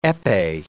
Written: epee

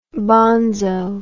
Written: bonzo

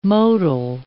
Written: modal

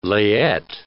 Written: layette

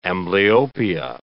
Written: amblyopia

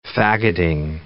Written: fagoting

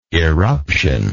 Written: eruptional

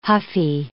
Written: huffy

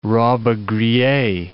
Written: Robbe-Grillet

